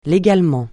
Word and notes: légalement: francais

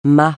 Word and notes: mât: francais

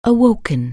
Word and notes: awake: anglais

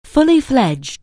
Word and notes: fully-fledged: anglais

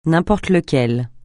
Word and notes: n'importe lequel: francais

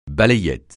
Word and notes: balayette: francais

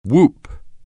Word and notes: whoop: anglais

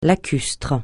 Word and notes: lacustre: francais

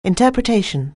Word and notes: interpretation: anglais

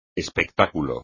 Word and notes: espectáculo: espagnol

